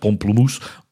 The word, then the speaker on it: pamplemousse: They borrow their name from the French word pamplemousse, which I guess would be pronounced pomplemousse, but not in that weird way, which is, I think, French for grapefruit.